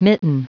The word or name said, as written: mitten